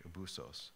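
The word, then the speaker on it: abusos (ἄβυσσος): We see in Greek written out here, but this is how you pronounce it in English, abusos.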